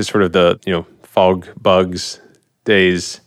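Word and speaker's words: FogBugz: You got the name right. That's good. – Yeah. – You're like fog bugs. – Yeah, yeah. Nailed it. Yeah. – And it's spelled with a Z.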